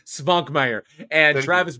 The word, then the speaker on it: Svankmajer: How do you pronounce that last name? – Jan Svankmajer. – Jan Svankmajer. – Svankmajer. Svankmajer.